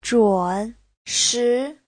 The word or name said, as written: zhun3shi2